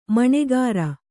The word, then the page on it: ಮಣೆಗಾರ: kannada